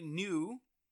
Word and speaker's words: Gnu: Nellie New, the G is silent, G-N-U, Nellie New in Llama Llama, opposite Jennifer Garner, hello.